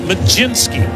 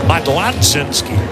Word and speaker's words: Mlodzinski: Carmen Majinski. You pronounce it Majinski. You spell it like it should be pronounced Modlodzinski, M-L-O-D-Z-I-N-S-K-I, pronounced Majinski.